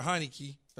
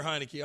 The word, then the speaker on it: Heinicke: Heinicke. Did I say it right? – Yeah. Taylor. Yeah. – Taylor Heinicke.